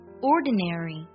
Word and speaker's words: ordinary: 第一个单词 ordinary，美音当中呢会把 na 这个音节读作 ne 的音，而英音当中呢会读作 n 的音，这个不太一样，但两种发音都是对的。